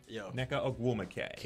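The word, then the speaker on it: Nneka Ogwumike: Neka Ogwumike.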